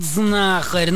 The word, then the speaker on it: знахарь: Думаю, ну, пусть скажет знахарь. Ну, сын, чтобы... Да, совершенно верно. Данное слово происходит от глагола «знать». А потому ударение в нем ставится на первый слог.